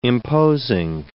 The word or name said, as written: imposing